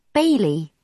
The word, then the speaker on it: Bailey: I'm Bailey. Nice to meet you. – Sorry, I didn't catch that. What's your name? – Bailey. – How do you spell it? – B-A-I-L-E-Y.